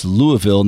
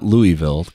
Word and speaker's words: Louisville: Did you know, I meant to tell you that you have been mispronouncing, it's Louisville, not Louisville, Kentucky.